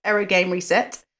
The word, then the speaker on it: 404 Game Reset: Sega and Yoko Taro's new game is called 404 Game Reset, pronounced Error Game Reset.